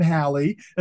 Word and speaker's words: Halley: That guy pronounced his name Halley, and so it should be how you say his name.